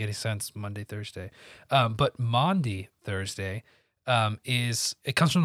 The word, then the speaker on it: Maundy: Yeah, Maundy.